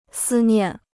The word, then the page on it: 思念: sī niàn